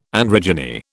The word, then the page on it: androgyny: British English